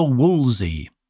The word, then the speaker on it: Wolseley: the Woolsey, or as my French friend used to call it, the Woolsey. It's spelt very oddly.